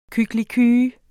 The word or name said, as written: kykliky